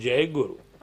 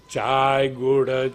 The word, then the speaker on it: Jai Guru: and early morning he couldn't pronounce Jai Guru. – A každý ráno on to nedovedl vyslovit. – Chai Guru, Chai Guru.